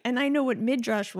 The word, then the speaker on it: Midrash: Midrash, is that how you pronounce it? – Yeah.